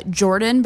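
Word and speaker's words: Geordon: My middle name is Jordan, but it's spelled with a G. It's G-E-O-R-D-O-N. So my parents put George and Gordon together.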